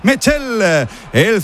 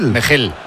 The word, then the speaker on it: Mechele: Mechel se pronunciará Mechel, ¿eh? – Sí, Mechel, yo creo. – De la pensaba zona... que era Mechele. – Mechel, ¿no? – Flamenca. – Sí, algo así Mechel.